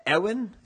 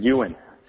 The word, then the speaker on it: Ewan: Well, Steve, we are joined with our first ever space freak of the week, and that is Dallas Ewan. Is that how you pronounce it, Dallas? – Oh, that's close, Sky. It's Ewan.